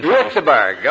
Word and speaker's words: Göteborg: That may not be pronounced right. Is it Göteborg? – Göteborg, okay.